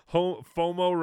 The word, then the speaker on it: FOMO: FOMO rhymes with homo? – Allegedly, sir.